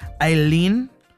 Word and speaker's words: Aline: And then we add, I'm going to try to say this Frenchly, in a French manner, because I believe it's Aileen, but it's spelled A-L-I-N-E, but I'm sure that's a way you can spell something along the lines of Aileen.